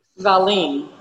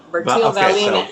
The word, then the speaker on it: Vallien: Is it Vallien? Is that how you say it? – Vallien. Vallien. So.